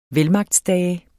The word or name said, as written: VELMAGTSDAGE